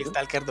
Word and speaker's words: Stalker: ¿Lo pronuncia bien? sí, Stalker 2.